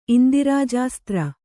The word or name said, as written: ಇಂದಿರಾಜಾಸ್ತ್ರ